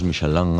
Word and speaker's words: Michelin: A ver, aquí en la Península Ibérica hacemos Michelin. – Sí, claro. Claro, pero la realidad, tú eres un chef internacional. – Ya, pero... – ¿Tú cómo lo pronuncias? – Me cuesta ser tan tontorraco, ¿no? Dice Michelin. – ¿Michelin? – Yo digo Michelin.